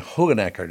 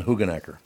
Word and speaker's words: Hoogenakker: He pronounced his name Hugenacker, doesn't he? – Yes. – John Hugenacker.